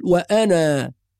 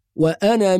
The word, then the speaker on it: وأنا: فلا ننطق الألف في هذه الكلمة عند الوصل، وإنما إذا وقفنا ننطقها نقول وأنا، فالألف هنا تكون ثابتة في الوقف محذوفة في الوصل، يبقى في الوصل نقرأ بحذف الألف وأنا من المسلمين.